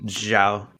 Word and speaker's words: Zhao: Zhao.